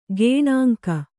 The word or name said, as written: ಗೇಣಂಕ